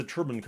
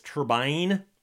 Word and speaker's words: turbine: and look up the word T-U-R-B-I-N-E, it'll say there's an alternate pronunciation. You can pronounce it either turbine or turbine. And generally speaking, the American pronunciation is turbine.